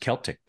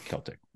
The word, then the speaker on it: Celtic: Could also be pronounced Celtic Pride as well, right? – Yes. So, either way. Celtic.